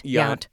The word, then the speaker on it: Yount: Yount.